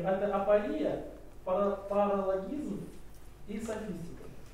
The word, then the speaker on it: апория: Ударение на предпоследнее слово «и» – апория.